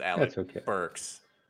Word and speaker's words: Alec Burks: I got Alex Burke instead of a Alec Burks. – Well, you just stick it again because it's Alec Burks.